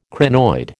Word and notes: crinoid: American English

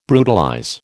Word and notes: brutalize: American English

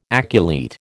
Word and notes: aculeate: American English

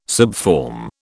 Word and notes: subform: British English

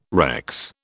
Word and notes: Rex: British English

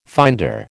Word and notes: finder: American English

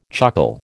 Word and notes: chuckle: American English

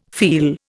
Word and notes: feel: Indian

